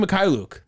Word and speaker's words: Mykhailiuk: Svi Mykhailiuk. That's how you say his name. Mykhailiuk.